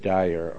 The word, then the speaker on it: Dyer: And then I had our own facilities installed in our church at Dyer, Arkansas. It's spelled D-Y-E-R.